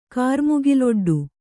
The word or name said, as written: ಕಾರ್ಮುಗಿಲೊಡ್ಡು